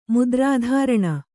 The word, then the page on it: ಮುದ್ರಾಧಾರಣೆ: mudrādhāraṇe